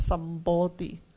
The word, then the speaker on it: Sambodhi: 正菩提就 Sambodhi， Sambodhi 这个字在汉译本有时候我们会看到他用音译叫三菩提，三菩提不是三种菩提，它是一个发音，就是 Sambodhi，就是等正觉，也是正菩提，真正的达到菩提了。